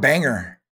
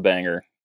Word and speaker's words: Bangor: I always pronounce it Bangor. – I pronounce it Bangor as well, but everyone around me has a Maine accent and they pronounce it Bangor.